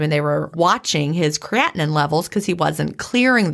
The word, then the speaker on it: creatinine: We've got ammonia, urea, creatinine. Is that, I always have to, is that creatinine. – Right? Creatinine. Creatinine. See, I think I always say it wrong.